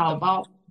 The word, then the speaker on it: Taobao: Taobao. Sí. Perfecto. – Taobao.